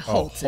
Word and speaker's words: Hoult: Holt? I believe Holt is how you say it.